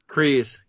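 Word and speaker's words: Kryze: Is it Kreese? Kreese? – Kreese, yeah.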